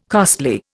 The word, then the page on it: costly: Indian